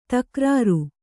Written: ತಕ್ರಾರ

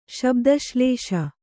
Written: ಶಬ್ದಶ್ಲೇಷ

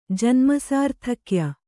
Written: ಜನ್ಮಸಾರ್ಥಕ್ಯ

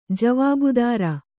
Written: ಜೀವಭದ್ರ

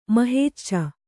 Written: ಮಹೇಚ್ಚ

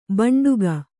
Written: ಭಾಂಡಿಕ

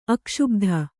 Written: ಅಕ್ಷುಭಿತ

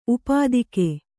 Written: ಊಪೋದಿಕೆ

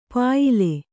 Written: ಪ್ಯಾಲೆ

